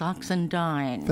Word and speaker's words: Oxendine: Is it Oxendine or Oxendine? – It's Oxendine.